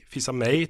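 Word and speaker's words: Fils-Aimé: Slik endte da Reginald Fizame Fizame? Fizame, Fizame tror jeg. Fizame, Fizame, tror jeg det uttales. – Fizame, ja.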